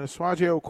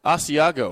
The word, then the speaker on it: Asiago: Asiago.